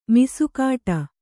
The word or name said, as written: ಮೀಸೆಗಡಿ